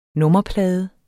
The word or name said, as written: nummerplade